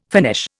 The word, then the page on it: Finnish: Indian